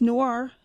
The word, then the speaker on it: noir: I guess it's noir, best pronunciation, correct? – Mm-hmm.